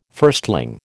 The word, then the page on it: firstling: American English